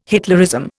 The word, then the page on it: Hitlerism: Indian